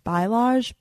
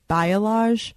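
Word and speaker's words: balayage: I got a, oh my God, I always say it wrong. Bialage, but it's Bialage, whatever.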